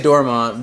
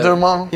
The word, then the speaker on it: Dormont: A little too French. I've been in Texas a long time. – So I like French. – We just say Dormont, but Dormont.